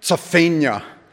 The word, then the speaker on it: Zephaniah: My guess is that if we were ancient Hebrew speakers, we would say his name something like Zephaniah. Almost like the end of Pennsylvania. But we totally Americanize it and go for Zephaniah.